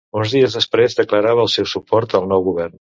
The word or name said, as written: declarava